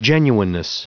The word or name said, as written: genuineness